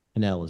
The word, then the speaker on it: Pennell: Pennell is with two N's and two L's, PENNELL.